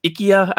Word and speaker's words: IKEA: And there's a lot of discussions in the Dwarven Forge communities about what IKEA, or actually it's IKEA, I guess is how you're supposed to pronounce it.